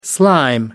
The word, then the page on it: slime: American English